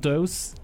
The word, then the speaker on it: Toews: Eh, basada en la novela de Miriam Toews. ¿Se pronuncia Toews? – Toews. sí, justamente.